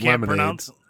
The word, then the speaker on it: lemonade: And the first one is lemonade. Lemonade. – What's the... Lemonade, yeah. – Lemonade. I pronounce it with the accent on the first syllable.